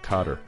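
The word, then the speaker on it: Kotter: It's the theme song to Welcome Back Carter. Or Welcome Back Cotter, I should say, to pronounce it correctly.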